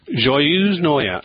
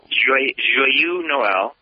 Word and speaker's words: Joyeux Noël: Joyeux Noël. – Joyeux Noël.